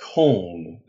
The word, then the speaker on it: Köln: So I'm still learning, and it's pronounced Köln, the city of Cologne, right? – Yeah, but actually in Dutch it's called Köln.